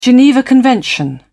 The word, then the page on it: geneva convention: British English